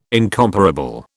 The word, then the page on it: incomparable: British English